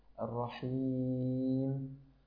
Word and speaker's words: الرحيم: يعني راعي التفخيم والترقيق بشكل أدق، ففي قوله تعالى الرحمن الرحيم الرحيم الرحمن الرحيم هكذا، يعني حاول أن تنطق الحاء وبعدها الياء مرققتين بهذا الشكل الرحيم، فلتتفضل مشكورا يا أخي الحبيب.